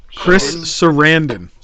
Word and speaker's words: Sarandon: Chris Shandon? – Chris Sarandon.